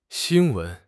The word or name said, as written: xin1 wen2